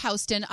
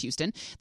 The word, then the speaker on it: Houston: And it says, the street's name is pronounced Houston, unlike the city of Houston, which is pronounced Houston. This is because the street was named for William Houston.